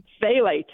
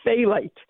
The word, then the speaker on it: phthalate: Phthalate. – Phthalate. – It's yeah. – a funny spelling. – It is. – PH and yes, PHTH. That's a hard thing to pronounce, but it's phthalate.